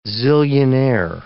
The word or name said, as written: zillionaire